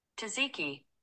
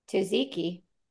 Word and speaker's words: Tzatziki: Tzatziki. Tzatziki. – Tzatziki. Tzatziki. – Okay, so you do. So it's you do pronounce the T.